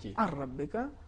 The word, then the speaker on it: ان ربک: دیکھیں ان ربک اگر ہم ادغام کریں گے تو یوں ہو جائے گا ار ربک نون بالکل حذف ہو جائے گا صحیح تلفظ اس طریقے سے ار ربک نون نہیں پڑھا جائے